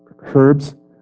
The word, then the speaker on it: herbs: it is the greatest among herbs. How do you say that? Herbs. Herbs. You don't hear the H. It's just herbs.